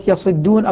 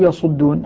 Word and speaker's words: يصدون: أما الكلمة الثانية فهي كلمة يصدون أو يصدون. حمزة وابن كثير وأبو عمرو وعاصم إذا قومك منه يصدون بكسر الصاد. والباقون الذين لم يرد ذكرهم بضم الصادع كما نطق به. – كسر الضم. – كسر الضم. – يصدون. – كسر الضم فكان اعتبر الأصل الضم الذين نص عليهم يكسرون الباقون يضمون.